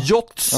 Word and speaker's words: yachts: I fyra, jag lär mig aldrig hur man uttalar det här, men jakter. – Ja. – Yachts, ja.